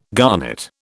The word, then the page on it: garnet: British English